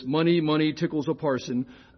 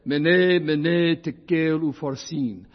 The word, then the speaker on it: Mene, Mene, Tekel, Ufarsin: A joke I heard 40 years ago made it go like this, money, money, tickles a parson. That's not how it goes, sorry. Okay, and English readers have struggled with those words for a long, long time. Maybe you've heard them said other ways. Here's how it's said in Aramaic, Mene, Mene, Tekel, Ufarsin.